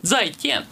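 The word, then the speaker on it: Зайтен (再见): Так, значит, существует одна фраза для прощения, которая произносится как «зайтен». – Это очень сложно. – «Зайтен». – «Зайтен». – Да, да. – «Зайтен». – «Зайтен». – «Тен». – Это два раза вверх.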